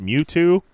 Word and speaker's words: Mewtwo: It's pronounced Mewtwo? – Yes.